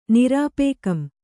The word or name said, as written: ನಿರಾಪೇಕಂ